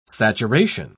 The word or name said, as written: saturation